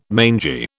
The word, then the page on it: mangy: British English